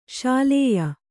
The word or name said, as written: ಶಲ್ಯೆ